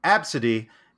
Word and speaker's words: Abcde: Do you know why they were making fun of little Absidy? – Wait, little what? – Yeah, that's right. Her name is Absidy, and that's spelled A-B-C-D-E.